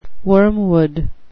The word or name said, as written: wormwood